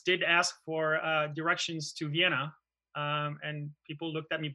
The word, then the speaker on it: Vienna: I think of Vienna, we pronounce it Vienna.